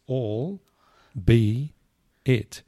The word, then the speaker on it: albeit: So it's three words to get. It's all, be, it. – And that's right. – You put them all together.